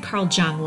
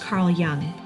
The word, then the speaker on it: Jung: Oh, by the way, as a brief segue here, I said Carl Jung last week, and it's pronounced Carl Jung.